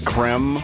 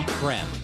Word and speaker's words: Creme: Creme? – Creme, yes. Lol Creme. – Has it always been pronounced Creme? – I believe so, yes.